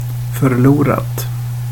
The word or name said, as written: förlorat